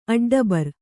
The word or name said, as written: ಅಡ್ಡಬರ್